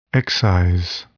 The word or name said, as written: excise